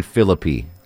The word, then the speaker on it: Phillippe: Kind of the metrosexual kind of, Ryan Phillippe.